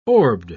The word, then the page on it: orbed: British English